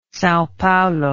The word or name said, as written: Sao Paulo